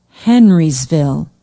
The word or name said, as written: henrysville